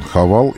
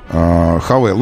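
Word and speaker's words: Haval: Многие задают себе вопрос, что он Хавал или Хавейл. Он, конечно, Хавейл. Мы произносим его как Хавейл.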